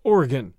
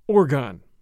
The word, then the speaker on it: Oregon: One suggestion, we pronounce my state of Oregon as Oregon, not Oregon.